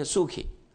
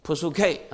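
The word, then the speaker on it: Pusuki (ψυχή): 那么我们通常是念 Suki，不过真正希腊文是有这个 P 的，是怎么念呢？ Pusuki 啊， Pusuki 我们的魂生命。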